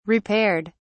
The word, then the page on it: repaired: British English